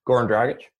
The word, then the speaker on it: Goran Dragic: I think Goran Dragic was on the podcast, and he was on the Heat. Am I saying that correctly? – Goran Dragic? – Yeah.